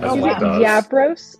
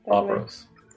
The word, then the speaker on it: Labros: Is it Yabros or Labros? – Labros. – With two L's? – Correct. – But they're not pronounced like a Y? – How would they be? – Because, like, if you were speaking Spanish, no. – That would be Yabros.